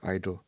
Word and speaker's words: idyll: And he comments on my recording of the practice session of his idyll. Or idle, probably, has to be pronounced idle.